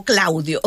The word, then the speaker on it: CL Audio: CL Audio, que se escribe como Claudio.